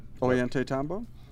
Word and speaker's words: Ollantaytambo: Is it Ollantumbo or whatever? Is that Ollantaytambo? – Much better. Much better than I said.